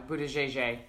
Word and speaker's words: Buttigieg: Buttigieg. – Okay. – That's how he says he pronounces it.